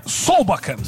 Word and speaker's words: Solbakken: É o Solbak, ou Solbeck, eu não me lembro a pronúncia correta dele, acho que é Solbak, né? É isso? – É, Solbakken.